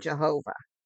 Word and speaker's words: Jehovah: Pronounced with the added vowels, it became Jehovah.